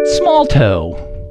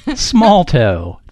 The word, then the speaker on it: smalto: And the word for round one is small toe. Small toe. That's spelled S-M-A-L-T-O. Small toe.